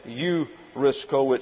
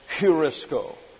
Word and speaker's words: heurisko: The rough breathing gives sort of a raspy sound at the beginning of the word. Rather than eurisko, it's heurisko.